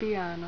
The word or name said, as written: piano